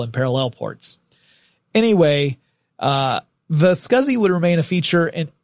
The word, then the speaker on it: SCSI: It was the first Macintosh to feature a small computer system interface, also known as a SCSI drive, S-C-S-I, but people call it SCSI. Not that it was SCSI, it's just how we pronounce it.